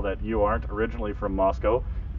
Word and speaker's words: Moscow: Now, the tendency is for everyone to want to pronounce Moscow, Moscow, because of Russia. But the locals bristle at that, so we're very particular on how you pronounce Moscow.